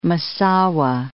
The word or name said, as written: Massawa